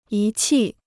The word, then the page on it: 仪器: yí qì